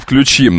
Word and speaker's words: включим: Не включим, а включим. На ключ никогда не падает ударение, кроме слова ключ.